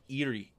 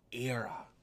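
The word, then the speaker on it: Éire: It's not Erie, it's Era.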